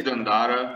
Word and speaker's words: Dandara: Am I saying that correctly? Dandara, or Dandara? – I always say Dandara. – Dandara.